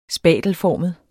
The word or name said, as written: spatelformet